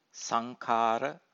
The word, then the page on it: sankhara: Pali